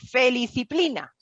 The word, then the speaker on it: feliciplina: La feliciplina es una, que lo digo bien, feliciplina. – Sí, es una mezcla entre la palabra felicidad y disciplina, eh, eh, y realmente en inglés es blissipline, ¿no? – Feliciplina.